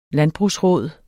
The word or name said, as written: landbrugsråd